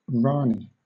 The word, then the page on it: rani: British English